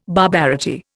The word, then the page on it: barbarity: Indian